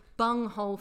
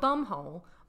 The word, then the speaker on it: bunghole: What do you mean you've been saying bung hole forever? Not bum hole. Bunghole.